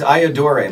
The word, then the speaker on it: Iodore: It's Iodore, like I, adore, you. Iodore.